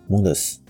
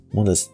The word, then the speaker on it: Mondeuse: 都是来自法国南部，虽然现在已经都没什么听过这个名词了。一个叫 Mondes，然后另外一个叫 Duhesa。 Mondes 的拼法就是 M O N D E U S E，Duhes 就是 D U R E Z A。